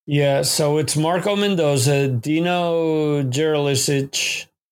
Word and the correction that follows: Jelusic: I pronounced that wrong. Jelusic. Okay.